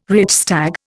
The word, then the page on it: Reichstag: Indian